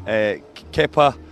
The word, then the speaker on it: Kepa: Kepa. – Let's stick with Kepa.